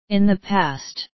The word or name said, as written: in the past